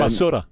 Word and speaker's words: basura: I saw the video about taking out the trash that's kind of La Barusa. Is it? Did I say that correctly? – And, basura. – What's that? – Basura. – La Basura. Okay.